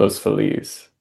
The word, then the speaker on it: Los Feliz: like if you were speaking Spanish, the correct pronunciation would be Los Feliz.